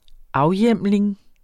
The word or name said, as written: afhjemling